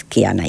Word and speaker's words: Kihana: Bueno, el caso es que la H muda y es Kiana ya.